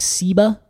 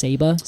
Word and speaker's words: ceiba: I know, we look at it and we think ceiba, but it is pronounced ceiba. C-E-I-B-A.